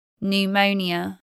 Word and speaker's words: pneumonia: PN spelling from Greek is silent P. Pneumatic. Pneumatic drill. Pneumonia.